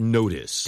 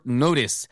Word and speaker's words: notice: Thank you for meeting me on short notice. – 딩동댕에서 on short notice. 발음 주의하세요. 이거 notice 이거 아니고요. – Notice. – 네, notice. No-u처럼 발음하셔야 돼요. Notice 아시겠죠? – Thank you for meeting me on short notice.